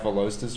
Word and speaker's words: Veloster: Basically, all these stanced out Velosters from around the world.